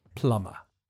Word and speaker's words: plumber: The pronunciation is a little bit difficult because there's a silent B in the written form. You just say plumber.